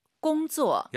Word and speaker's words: 工作: 일하다 공조 쌍지읒하지 마시고 공조. – 약간 지은 느낌으로 힘 빼고 발음하시는 게 포인트입니다.